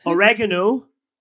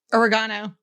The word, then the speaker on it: oregano: Oregano. – Oregano. – Oregano. – Really? – Yeah. – You're putting the emphasis on the wrong syllable.